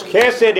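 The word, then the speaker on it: chesed (חסד): That first letter, the chet, is very hard to pronounce. But it's pronounced chesed.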